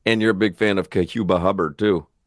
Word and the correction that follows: Chuba Hubbard: He ain't said his name right all week. – I believe it's Chuba Hubbard.